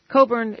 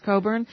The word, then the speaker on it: Cockburn: It's Cockburn. It's spelled Cockburn, but it's pronounced Cockburn.